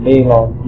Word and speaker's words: Balog: Pronounce it Balog, B-A-L-O-G.